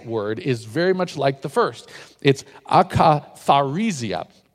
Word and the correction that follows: akatharsia: And I didn't pronounce that right. It's akatharsia. There we go. I can get that right.